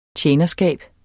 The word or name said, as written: tjenerskab